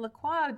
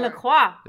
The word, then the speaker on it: LaCroix: Apparently that is pronounced LaCroix. – Oh, God. – I've discovered, because I looked it up online after someone said that I was being really pretentious by pronouncing it LaCroix. And I was like, it's LaCroix, darling.